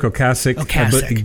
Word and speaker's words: Ocasek: Well, it's Rick Ocasek, but everybody says Rick Ocasek, so it's like the Van Gogh, Van Gogh thing.